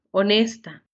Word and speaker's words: honesta: How do you say honest? – Honesto, honesta.